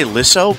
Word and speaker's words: Lissow: Is that how you say it? Lisso? – I say Lisso, but maybe I've been saying it wrong.